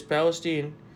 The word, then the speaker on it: Palestine: It's Palestine? – It is Palestine.